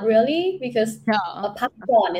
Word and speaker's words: Pakorn: P-A-K-O-R-N. – Yeah, pakon. Pakon. Mm-hmm. Actually, the last syllable, it has to go down.